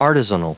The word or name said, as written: artisanal